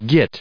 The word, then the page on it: git: American English